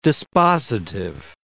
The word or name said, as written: dispositive